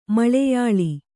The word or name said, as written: ಮಲಯಾಳ